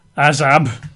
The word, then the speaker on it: azab: Well, the Hebrew word here for forsaken is azab. In English, you'd spell that as A-Z-A-B. I guess in America, A-Z-A-B.